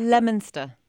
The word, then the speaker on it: Leominster: I mean, if you were to look at a place like I'm going to pronounce this wrongly, but Leominster in Massachusetts, I think it's Leominster. – Leominster.